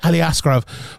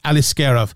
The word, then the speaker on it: Aliskerov: Sorry, I always mispronounce his name. So it's Ali Askarov. I always say Ali Askarov for some reason, but it's Ali Askarov.